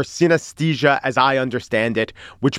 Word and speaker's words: synesthesia: How do you pronounce synesthesia? – Synesthesia. – Good job.